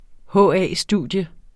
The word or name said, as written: HA-studium